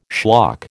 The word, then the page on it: schlock: American English